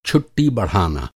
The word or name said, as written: बढ़ाना